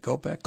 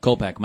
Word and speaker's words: Kopech: How do we pronounce that? Kopech. – Kopech. Michael Kopech. Yeah.